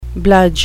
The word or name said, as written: bludge